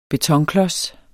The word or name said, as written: betonklods